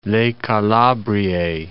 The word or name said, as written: Le Calabrie